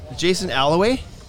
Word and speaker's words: Jason Alloway: Is Jason Alloway? Is that how you say it? – That's me.